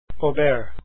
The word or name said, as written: auber